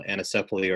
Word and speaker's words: anencephaly: Our first child died of a condition called anencephaly, or some pronounce it anencephaly.